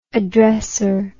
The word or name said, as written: addresser